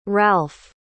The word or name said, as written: Ralf